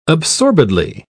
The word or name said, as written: absorbedly